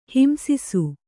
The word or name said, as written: ಹಿಂಸಿಸು